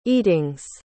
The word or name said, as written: eatings